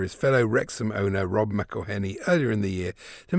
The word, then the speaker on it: McElhenney: It's McElhenney, McElhenney. What ways to massacre and mispronounce it, there are many. It's McElhenney, McElhenney. If there was doubt, now there won't be any. It's McIlhenny.